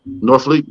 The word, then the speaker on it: Norfleet: But this one is the lady by the name of Tia Nor, how you pronounce that, man? – Norfleet, yeah, there you go.